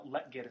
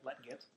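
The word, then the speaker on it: Lletget: In the midfield, Joe Corona comes back, Mix Diskerud, Dilover Duca, Jared Jeffrey, Sebi Letjit. Letgit is actually the pronunciation. – Yep. – Letgit.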